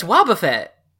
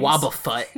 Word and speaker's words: Wobbuffet: That's not how you pronounce it. – How is it you pronounce it? It's Wobbuffet. – Well, I'm very bad at pronouncing things. – Wobbuffet. I hate it. – Oh, wait, so it's like Warren Buffett.